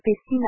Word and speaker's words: Epstein: Or, Jeffrey Epstein était américain et se prononçait lui-même Epstein à l'anglaise, avec le son AI propre à l'anglaise américain. Comme l'a rappelé le président du CRIF, un élève de 5e siècle en anglais, Epstein se prononce Epstein.